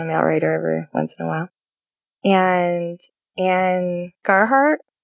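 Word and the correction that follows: Ann Gabhart: I'm saying that wrong, I think. And Whittemeyer is another one. And or Francine Rivers, throw her in. – Would you say any of those authors inspired you to write? – You know, it was Anne Gabhart. I said her name wrong.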